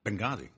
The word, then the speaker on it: Benghazi: How should I pronounce it? – Benghazi. But there's Benghazi. A half a pause between.